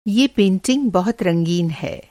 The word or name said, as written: रंगीन